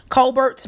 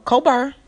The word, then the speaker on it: Colbert: people should pronounce it Colbert, but Colbert, in November that she would give the presidential bid a long and hard thought for a consideration.